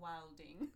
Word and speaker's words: wildin: Okay, because like they wildin. – We don't pronounce the D in wilding. The D wildin. – And the G wildin. Thank you. The D and the G? Oh, silent.